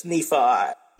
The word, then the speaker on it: Nephi: A lot of people say Nephi, but it's Nephi.